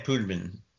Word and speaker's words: putamen: putamen. I hope I'm saying that right. – Mm-hmm. Caudate putamen. Yeah.